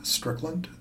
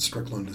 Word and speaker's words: Strickland: Rick Strickland, and Strickland is spelled S-T-R-I-C-K-L-A-N-D.